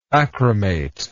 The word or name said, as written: achromate